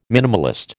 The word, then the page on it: minimalist: American English